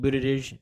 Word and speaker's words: Buttigieg: Yeah, Buttigieg. – Pete Buttigieg.